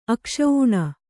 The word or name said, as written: ಅಕ್ಷೂಣ